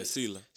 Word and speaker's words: Sela: The name of the project, first off, is, how you pronounce it, Salah? – Yeah, Sela. – Sela, excuse me, Sela.